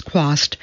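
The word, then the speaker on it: Chwast: Chaswat, pronounced Quast,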